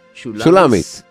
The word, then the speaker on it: Shulamis: Uh, how do you call it? How do you spell it? Shulamit or Shulam, Shulamit? – Shulamis, Shulamis, Shulamis, uh, and uh, whatever you know, just uh, auditorium.